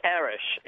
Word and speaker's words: Aresh: It's Arish, A-R-E-S-H.